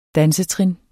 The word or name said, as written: DANSETRINS